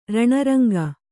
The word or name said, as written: ರಣರಂಗ